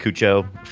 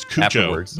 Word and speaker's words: Cuoco: I don't know if you know, but Kaylee Cuoco from The Big Bang Theory.